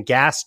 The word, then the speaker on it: Gass: And then my last name, Gass, G-A-S-S, otherwise pronounced Gass.